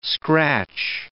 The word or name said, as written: scratch